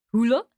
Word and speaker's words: Hüller: Hüller, Anatomy of a Fall, which we played at CIFF, obviously, to great fanfare.